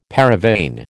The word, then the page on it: paravane: American English